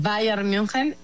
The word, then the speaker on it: Bayern München: A ver, el América está nominado junto con el Barça femenil, junto con el Bayern München.